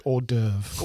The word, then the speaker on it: hors d'oeuvre: I think it's pronounced hors d'oeuvre.